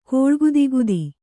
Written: ಕೂಳ್ಗುದಿಗುದಿ

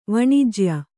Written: ವಾಣಿಜ್ಯ

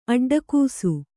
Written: ಅಡ್ಡಗಿಸು

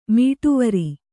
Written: ಮೀಟುವರಿ

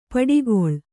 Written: ಪಿಡಿಗಲ್

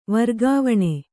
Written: ವರ್ಗಾವಣೆ

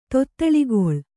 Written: ತೊತ್ತಳಿಗೊಳ್

